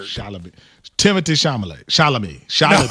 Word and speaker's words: Chalamet: it Timothy Chalamet? – He said Chalamet.